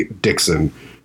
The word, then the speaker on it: Dickson: Because my last name happens to be Dixon, and it's spelled D-I-C-K-S-O-N.